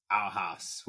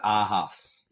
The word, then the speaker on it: Aarhus: So he's absolutely butchered the name. I think it's Aarhus, we'll pronounce it, Aarhus.